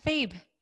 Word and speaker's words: Thebe: Is it Thebe or Thebe? – Correct. Thebe.